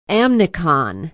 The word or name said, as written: amnicon